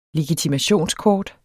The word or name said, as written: LEGITIMATIONSKORT